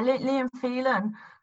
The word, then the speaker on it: Liam Phelan: So your last song on this list is called "Insane" by Liam Phelan, if I've said that correctly. – That's right. Yeah, Liam Phelan, and he's a great example.